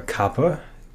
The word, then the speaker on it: kapha: I've talked about in previous episodes, or kapha, K-A-P-H-A, the H is silent.